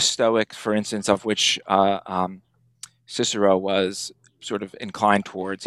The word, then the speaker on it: Cicero: What does Cicero have to say about, or Cicero, as my friend pronounces it? – Yeah, it's funny.